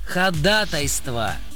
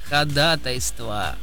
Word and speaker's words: ходатайство: И хоть в юриспруденции многие ошибочно полагают, что вариант со вторым ударным «а» — это профессионализм, смело утверждаю обратно, это наигрубейшая ошибка. Потому что правильно говорить «ходатайство», Игорь, «ходатайство».